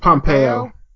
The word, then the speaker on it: Pompeo: Secretary of State Pompeo. Is that how you say it? – Pompeo. – Pompeo.